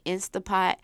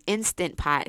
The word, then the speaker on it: Instant Pot: But mind you, in my mind, for some reason, I thought an Instapot, an Instant Pot, because that is the proper way to pronounce it.